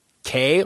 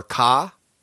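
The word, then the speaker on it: Kaa: Scarlett Johansson as Kay or Ka? – Ka.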